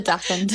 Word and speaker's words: dachshund: I pronounced it the German way. – Oh. – The dachshund.